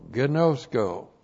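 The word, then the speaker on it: gnosko (γινώσκω): So we start out with gnosko. G-I-N, long O, S-K, long O, gnosko.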